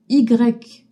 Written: y trema

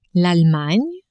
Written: Allemagne

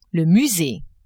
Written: musee

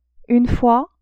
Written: une fois

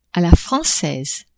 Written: a la francaise

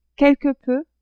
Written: quelque peu

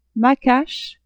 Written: macache

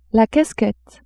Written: casquette

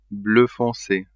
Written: bleu fonce

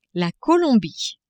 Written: Colombie

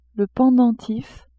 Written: pendentif

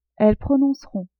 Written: elles prononceront

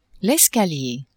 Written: escalier

